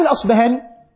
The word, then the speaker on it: الأصبهاني: كلمة الأصبهاني أحيانا نقف على بعض الناس يقول أبو نعيم الأصبهاني وبعضهم يقول أبو نعيم الأصفهاني، هذا صح وهذا صح. المشارقة لهم نطق يقولون الأصفهاني والمغاربة يقولون الأصبهاني، واضح؟